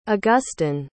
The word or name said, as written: augustin